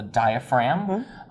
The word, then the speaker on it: diaphragm: This is a set of lungs, and below the lungs is the muscle, the diaphragm. And the G in diaphragm is silent.